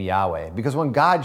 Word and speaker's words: Yahweh: And nobody's 100% sure how this is pronounced, but we know that it's connected to Echwe, right? This I am. And most scholars believe that it's pronounced Yahweh, which is He is.